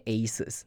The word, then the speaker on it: Asus: 校的 IT 的部门，我就问他说，哎，那个我的笔电坏了，你可以帮我看一下吗？就说，哎，你可以帮我看一下这个 ASUS 吗？然后这是一个女生，大概十来岁吧，大学生的样子，然后她就很认真看着我，然后对着我说。 Asus，然后我就干嘞，这是我们家的牌子，然后你纠正我要怎么念，然后我就哦，Asus 怎么样怎么样，所以我一开始念 ASUS 的时候，他说你不对，你要念 Asus，然后真的心里就是鬼然趴会，就是。